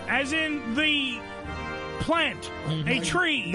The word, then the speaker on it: Chree: T-R-E. – Oak tree? – No, no. However, it's spelled C-H-R-E-E, but it's pronounced tree, as in ch, with the C-H, like ch, cheese, but ch, and then re, so tree.